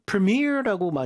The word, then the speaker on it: premier: 우리는 이제 미국 발음에서 premier라고 많이 하는데 영국 발음은 premier인가요? – 그렇죠. Premier, Premier League, Premier Soccer Organizations. – Pre가 아니라 pre 더 가깝죠. 음흠.